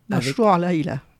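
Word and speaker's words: Mashrou' Leila: Mashrou Laila. – Tu prononces bien mieux que moi, avec le musicien renommé mondialement, Eric Truffaz.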